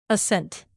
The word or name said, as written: ascent